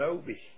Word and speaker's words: obi: And I know when we were in Japan, especially the women, and that's called an obi. I believe that's the way it's pronounced, OBI.